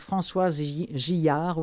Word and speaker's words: Françoise Gillard: Ça existe en audio, c'est fait chez Écoutez lire, c'est lu par Françoise Gillard, oui, ça se prononce comme ça.